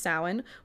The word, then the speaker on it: Samhain: It's pronounced Samhain, which means summer's end, and this festival marked the end of the summer harvest and the start of the dark winter.